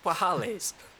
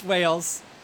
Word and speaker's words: whales: I thought it was wahales. – No, it's Wales.